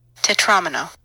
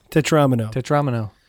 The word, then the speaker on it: Tetromino: I was looking up the pronunciation. – Oh. – Okay. – What was it? – Tetromino. – There you go. – Tetromino. Thank you, Wiktionary.